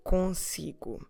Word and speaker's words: consigo: consigo.